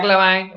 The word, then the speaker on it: LaVine: Eh, Zach Lavine o Lavin, dependiendo de cómo lo quieran pronunciar,